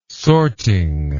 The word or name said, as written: sorting